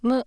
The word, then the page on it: mu: Japanese